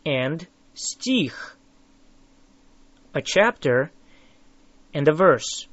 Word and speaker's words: стих: Стих. Стих. Say их. Их. Тих. Тих. Now add an S at the front of it.